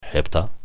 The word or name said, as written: hebta